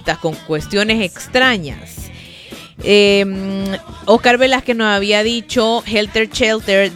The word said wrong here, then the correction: Helter Skelter: de... ¡Ay! Siempre lo digo mal. Helter Skelter de los Beatles, esa canción.